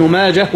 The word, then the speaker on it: ماجه: فهل ننطقها بالهاء ولا بالتاء؟ الهاء صحيحة والتاء صحيحة، والهاء أكثر والتاء أقل، فكلاهما صحيح، لكن المعظم أو الغالب بالإيه؟ بالهاء، الهاء سكونا أو إيه؟ أو وصلا. لا، ابن ماجه وغيره.